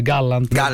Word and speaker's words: Gallant: Io oggi ho goduto quando un signore che fa il ministro della difesa in Israele, che si chiama Joab, come si pronuncia, David? Gallant.